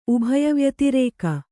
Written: ಉಭಯವ್ಯತಿರೇಕ

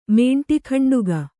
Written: ಮೇಂಟಿಗಂಡುಗ

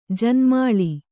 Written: ಜನ್ಮಾಳಿ

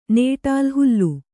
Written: ನೇಟಾಲ್ ಹುಲ್ಲು

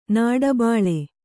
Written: ನಾಡಬಾಳೆ

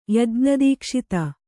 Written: ಯಜ್ಞದೀಕ್ಷಿತ

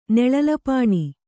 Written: ನೆಳಲಪಾಣಿ

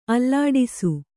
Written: ಆಲೋಡಿಸು

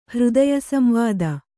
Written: ಹೃದಯಸಂವಾದ

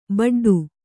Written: ಬಡ್ಡು

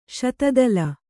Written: ಶತದಳ

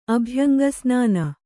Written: ಅಭ್ಯಂಗಸ್ನಾನ

